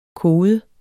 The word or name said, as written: kode